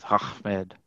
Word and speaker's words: Ahmed: So even I appreciate the pronunciation that you have with Ahmed. – Because yes. – That's how you say it. That's how they say it. – Oh, yeah. Yeah, you got to throw a little sand in the back of your throat.